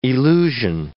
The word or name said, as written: elusion